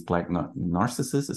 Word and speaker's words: Black Narcissus: Just because I'm seeing it right now, I mean, the color win for cinematography is Black Narcissus.